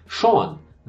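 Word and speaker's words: Sjón: Então, o co-escritor, um dos roteiristas desse filme, é o habitual letrista, parceiro, poeta islandês Sean. Né, que se escreve S-J-O-N, Sean, é o Sean,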